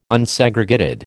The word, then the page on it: Unsegregated: American English